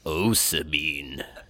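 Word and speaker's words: ocimene: Pronunciation guide says osamine.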